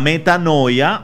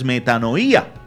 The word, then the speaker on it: metanoía: Y aquí venimos a hablar de otro término que en la renovación carismática es muy utilizado, creo que a veces erróneamente, la metanoia, o según su pronunciación correcta es metanoía, porque tiene su tilde en otro lugar.